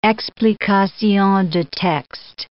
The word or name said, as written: explications de texte